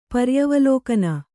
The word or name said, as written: ಪರ್ಯವಲೋಕನ